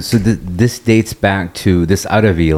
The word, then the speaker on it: Arvi: It's called Arabi, A-R-V-I. – Okay. – Because the Tamil people couldn't pronounce Arabi. – Mm-hmm. – They kept saying Arabi because they didn't have a ba.